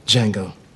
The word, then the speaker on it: Django: Django. – Can you spell it? – D-J-A-N-G-O. The D is silent.